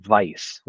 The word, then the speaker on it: Weiss: So I not to get on pronunciations, but I think that's like Weiss, like Edelweiss. – Oh, okay. – Berliner Weiss.